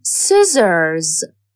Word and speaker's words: scissors: C is a silent letter in scissors, jacket.